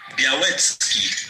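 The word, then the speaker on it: Białecki: Und ähm, so spricht man Sebastian Bialetski tatsächlich richtig aus. – Sebastian Bialetski.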